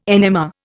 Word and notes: NMR: Indian

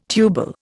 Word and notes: tubal: Indian

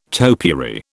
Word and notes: topiary: British English

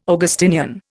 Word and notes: Augustinian: Indian

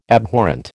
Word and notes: Abhorrent: American English

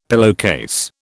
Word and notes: pillowcase: British English

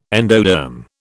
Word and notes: endoderm: British English

